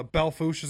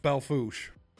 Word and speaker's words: Belle Fourche: Belfouche is a good example. That's the town I was born in. It's spelled Belle Fourche, but it's pronounced Belfouche.